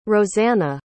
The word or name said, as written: rosanna